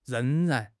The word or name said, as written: reng2 ran2